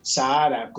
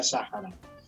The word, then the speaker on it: Sáhara: Eh, del Sáhara o Sahara, como se conoce, como quizás lo conocemos ahí. Acá, acá se pronuncia Sáhara.